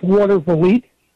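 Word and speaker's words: Watervliet: It's Watervali.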